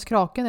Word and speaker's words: Kraken: Jag vet inte om det uttalas kraken eller kre, kre, kraken? – Kraken. – Kraken. Kraken, det är ju liksom från det Viking vikinganamn.